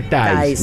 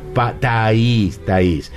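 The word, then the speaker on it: Thays: Porque la gente dice Thaïs. No. Mónica Caen Danvers, parienta de Thaïs, nos decía, así se pronuncia, Thaïs.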